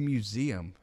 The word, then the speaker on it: MUZMM: Is it, because one of my friends was like, I think it's museum. And I'm like, So what is it? – Museum. – M-U-Z-M-M.